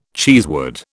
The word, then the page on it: cheesewood: British English